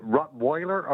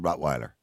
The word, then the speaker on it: Rottweiler: W in German is a V. Yeah. V sound. – Yeah, but you're not living in Germany, but you're living in Europe. So in Europe, we pronounce it Rottweiler.